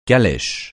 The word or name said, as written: calèche